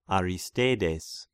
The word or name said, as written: Aristeides